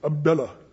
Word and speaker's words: Abdullah: And if you think I'm making fun of it, that's how they say it over there. When you say it in the Middle East, you have to kind of loosen your lips, Abdullah.